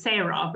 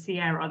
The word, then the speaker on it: Sierah: I originally thought her name was Sarah, but it's Sierra. That's how they pronounce it.